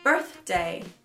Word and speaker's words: birthday: Aqui você tem que ser bem sutil no TH. Birth, birth, birthday birthday.